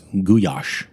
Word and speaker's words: goulash: Yeah, her goulash, or I've learned it's pronounced goulash when mm-hmm.